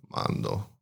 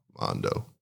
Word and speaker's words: Mondo: But beside that, yeah, I think my name, since, it's not the most common people normally go to, Mando or Mandu and Salas, but it's just Mondo Salas. And they're like, why don't you spell it with the O? And I'm like, could you say it in Spanish? It's Armando, Mondo, Mondo.